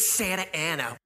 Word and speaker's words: Santa Ana: And that had another really big crowd at Santa Ana. I'm sorry. It just looks like Anna to me because there's only one N. – One. – They pronounce it Santa Ana.